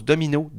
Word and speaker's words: Domeno: Et là, imagine-toi donc, arrive-t-il pas un remix à la sauce du jour du Achy Breaky Dance, remixé par Domino, qu'on prononce Domino, D-O-M-E-N-O.